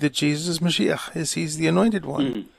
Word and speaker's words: Mashiach: Mashiach? – Yeshua and Messiah. – In Assyrian, it's Mashiach or Mashiacha? – Mashiach. Mashiach. – Mashiach, yeah, that means the anointed one.